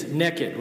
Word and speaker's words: naked: And I'm from Georgia, so we pronounce naked. It's naked, right? N-E-K-K-I-D. Naked.